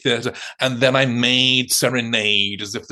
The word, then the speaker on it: Serenade: But before we start, I think the most important thing to talk about is, do we pronounce Serenade correctly? – I was about to say this. Well, as you know, the Balanchine world does pronounce it Serenade, and the Balanchine world is wrong, because the Balanchine himself, from at least the 1950s, was calling it Serenade.